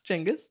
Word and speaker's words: Genghis: I'm always trying to, is it pronounced Genghis? – Genghis.